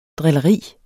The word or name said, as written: drilleri